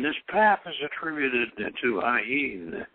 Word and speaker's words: Ayin: And he says, he's pronounced Ayin with a slight nasal twang, which means the eye, referring to the eye of Shiva, said to be atrophied into the pineal gland.